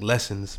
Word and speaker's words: Less Sins: And I guess it's supposed to be like Lessons, but it's spelled like Less Sins.